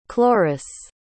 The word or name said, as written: cloris